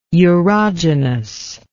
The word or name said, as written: urogenous